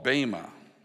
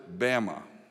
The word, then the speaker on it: Bema: often called the Bema seat. But it's also called Bema. And some even pronounce it Bama.